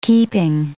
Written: keeping

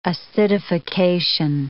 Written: acidification

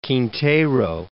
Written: Alvarez Quintero

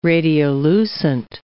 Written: radiolucent

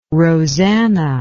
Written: roseanna

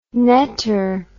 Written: netter

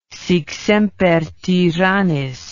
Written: sic semper tyrannis

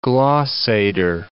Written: glossator

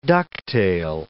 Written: ducktail

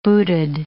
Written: booted

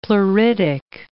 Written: pleuritic

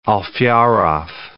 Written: Alferov

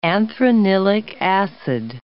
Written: anthranilic acid

